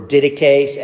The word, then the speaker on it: Didache: This is an excerpt from the Didache, or Didache, as some people pronounce it, otherwise known as the 12 Apostles.